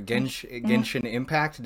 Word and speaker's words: Genshin Impact: So Anna, we were talking a little bit about a game that you were playing earlier, Genshin Impact.